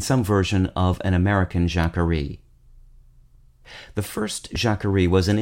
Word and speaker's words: Jacquerie: Today's word is Jacquerie, spelled J-A-C-Q-U-E-R-I-E.